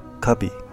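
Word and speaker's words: Kabi (กระบี่): 泰国甲米，其实如果按照直译的发音，准确的说应该叫做 Kabi。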